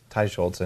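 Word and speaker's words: Teicholz: You're one of the people leading the national conversation along with like Nina Tur. I would say her name wrong. – Ty Schultz.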